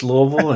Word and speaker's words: Louisville: It's Louisville. And, it's actually probably supposed to be pronounced Louisville because we're named after King Louis from France. But okay. Louisville is the way it is.